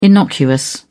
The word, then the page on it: innocuous: British English